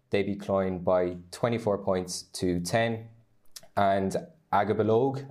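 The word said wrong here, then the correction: Aghabullogue: am I pronouncing that right? – Ahabalogue. – That's the one.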